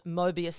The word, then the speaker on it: Möbius: So 1 in 4 million people have this neurological disorder called Möbius syndrome. Is that correct? Have I pronounced that? – Yeah, that's correct.